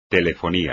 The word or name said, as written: telefonía